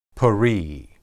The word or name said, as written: peri